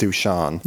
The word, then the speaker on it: Duchamp: another major influence on Fluxus was the French-American painter Marcel Duchamp, it's spelled like Duchamp, mm-hmm. but it's pronounced Duchamp.